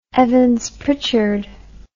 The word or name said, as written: evans pritchard